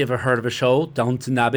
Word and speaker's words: Downton: And it's pronounced Downton, downtown. It's Downton.